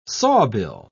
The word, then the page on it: sawbill: British English